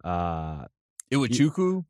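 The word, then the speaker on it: Iwuchukwu: I can't really pronounce his name. – Iwachukwu. – Yeah. – There you go.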